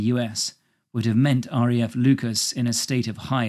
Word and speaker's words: Leuchars: Leuchars, pronounced like the name Lucas, which occurs later in the poem, used to be an RAF base from which fighter planes flew countless defence and reconnaissance sorties between 1920 and 2015.